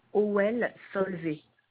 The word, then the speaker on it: Howel-Solvay: Howel-Solvay. OL, ça s'écrit OWEL, et plus loin, Solvay, SOLVET.